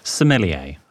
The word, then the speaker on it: sommelier: And now, how would you, Gin Divas, pronounce S-O-M-M-E-L-I-E-R? Because this was Jordan's attempt. Eating out. – Williamsmore, Somalia. What is it? – Sommelier. – Williamsmore, Sommelier.